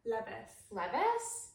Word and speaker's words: Leviss: Is that how you say it? With a double S? – Is it L-E-V-I-S-S? – Yeah. – Levis? – Raquel Levis.